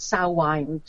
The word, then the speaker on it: Samhain: I know it's spelled with an M, but it's Samhain, just to confuse everyone.